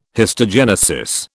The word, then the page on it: histogenesis: British English